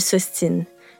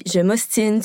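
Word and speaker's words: s'obstine: Lorsque la lettre B se retrouve entre un O et un S, elle est muette. Donc, on ne prononce pas le B quand on conjugue « obstiné » en forme pronominale. On dit « je m'ostine »,« tu t'ostines »,« il, elle s'ostine », mais on garde quand même le B pour le mot « obstination ».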